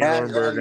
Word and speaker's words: Rodenburg: Did I say that right? Shane Rodenburg. You got it. Perfect.